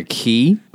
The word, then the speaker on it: cay: Yeah, but it's spelled C-A-Y, so I'm going to pronounce it K for this purpose. So this is Watermelon K or Watermelon Key, but for the purpose of my joke, I'm going to stick with K, which, by the way, if you look it up on the dictionary, which I just did, that's the first pronunciation that's listed.